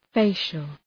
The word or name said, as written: facial